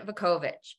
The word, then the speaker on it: Vukovich: I'm your host and joining me today is I'm going to butcher your last name, Allison Vukovich, who is.